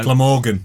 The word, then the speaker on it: Glamorgan: I'm probably pronouncing that wrong. – No. – Glamorgan.